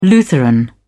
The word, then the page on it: lutheran: British English